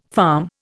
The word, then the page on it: farm: Indian